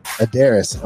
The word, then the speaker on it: Aderes: Is that how you say it? Is that like how you? – Yeah, I say it Aderes, but it's not my real name, so you can say it however you like.